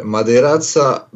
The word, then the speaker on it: Madiraca: It's Hrvoje, Madiraca, but on this matter, Harvey is cool.